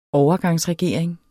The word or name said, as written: overgangsregering